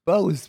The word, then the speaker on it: Bose: How do you say that? Bose speaker.